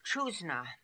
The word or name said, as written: Choozna